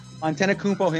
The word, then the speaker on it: Antetokounmpo: Mr. Antetokounmpo himself.